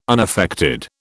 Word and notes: unaffected: British English

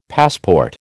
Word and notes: passport: American English

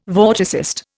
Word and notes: vorticist: Indian

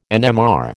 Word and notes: NMR: American English

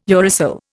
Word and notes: uracil: Indian

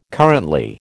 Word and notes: currently: American English

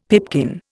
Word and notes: pipkin: Indian

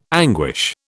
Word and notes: anguish: British English

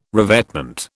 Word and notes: revetment: British English

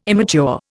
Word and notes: immature: Indian

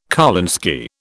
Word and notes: kolinsky: British English

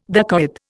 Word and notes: dacoit: Indian

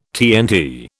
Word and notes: TNT: British English

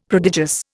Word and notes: prodigious: Indian

